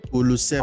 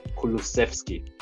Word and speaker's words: Kulusevski: Kulusevski. – I was a bit surprised. Kulusevski.